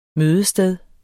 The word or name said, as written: mødested